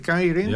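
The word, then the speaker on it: Keirin: Of hoe spreek je het? Kierin. – Ja.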